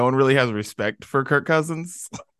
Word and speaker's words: Kirk Cousins: It's Kirk Cousins. Kirk Cousins. You end his name and start his first name and start his last name all in the same syllable there.